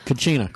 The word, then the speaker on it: cucina: Cucina.